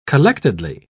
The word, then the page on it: collectedly: British English